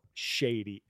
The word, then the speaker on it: Shadie: Bill, so the original Shady, but it's spelled Bill Shady, S-H-A-D-I-E.